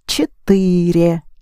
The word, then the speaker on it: четыре: And the numeral 4 is четыре. There's е in the ending. 3 syllables. Четыре. Which comes from тетра, Greek.